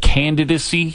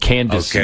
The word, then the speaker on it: candidacy: Like, I think he said, instead of candidacy, he said candacy.